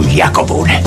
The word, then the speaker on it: Iacovone: Petrovic, Giovannone, Cimenti, Panizza, Dradi e Nardello, Gori, Fanti, Iacobone, Selvaggi e Caputi. – E l'accento cadeva su Iacobone.